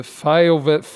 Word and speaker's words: FYEO: Warte mal, wir müssen aber erklären, Fire wird das ausgesprochen, ne? Aber es wird geschrieben FYEO, for your ears only. Weil nicht, dass ihr nach Fayo mit EI oder AI sucht, ne?